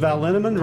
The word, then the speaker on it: Linneman: The equally mysterious Tracy Z, Aviva Lael, Alicia Lemus, Rebecca Hughes, Val Linneman, Rhymes with Cinnamon,